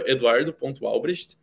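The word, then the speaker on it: Eduardo Albrecht: Uh, meu arroba é arroba eduardo.albrecht. É, o meu sobrenome se escreve A-L-B-R-E-C-H-T.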